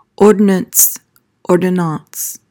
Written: ordonnance

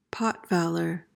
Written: pot-valor

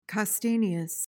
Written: castaneous

